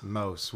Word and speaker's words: Maus: Mouse, oui, je parle. – Ah, je sais pas, ça s'écrit M-A-U-S, alors... – Ouais, mais c'est allemand, c'est allemand.